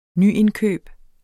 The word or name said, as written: nyindkøb